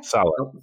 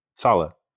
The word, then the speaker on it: Saleh: Saleh. – Okay. – Saleh.